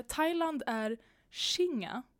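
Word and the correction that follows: Singha: Eller Singa. Singa. Eh, singa. – Mm. Är det sing streck? – Nej, eh, S-I-N.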